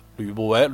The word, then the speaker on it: 吕不韦: 开办买卖，开始商业活动啊，其中最有名儿的就是这个吕吕不韦，吕不韦啊，正确读音说这二声吕吕不韦，《